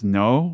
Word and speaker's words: Noh: So the story begins with this vision of this mysterious woman in a kimono and what is called a, I believe it's pronounced no mask, N-O-H, Japanese theater mask.